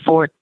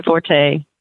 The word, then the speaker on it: forte: You say fort. You don't say forte. So that's why, at least from APH narrators, you're probably going to hear fort, because it's hammered into us that that is the correct way to say it.